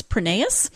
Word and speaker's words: Prineas: And she says it is Prineas. It is Greek. And actually in Greece, they would pronounce it Prineas. But when her husband's family emigrated, they changed it to Prineas.